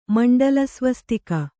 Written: ಮಂಡಲಸ್ವಸ್ತಿಕ

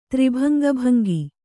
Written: ತ್ರಿಭಂಗಭಂಗಿ

